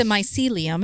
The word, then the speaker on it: mycelium: So, it sounds like the mycelium, am I saying that right?